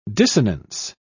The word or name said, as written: dissonance